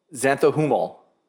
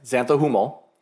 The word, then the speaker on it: xanthohumol: The substance is called, and I listened to this before the podcast so I could say it right, xanthohumol. Yes, xanthohumol.